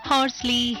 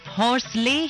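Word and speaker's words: Horsley: अच्छा, वैसे आम बोलचाल में इस जगह को ना हॉर्सले हिल्स भी कह दिया जाता है, लेकिन अगर इंग्लिश में नाम की स्पेलिंग और कलेक्टर साहब के नाम पर गौर करें तो सही प्रोनंसिएशन होगा, यानी सही उच्चारण होगा हॉर्सली।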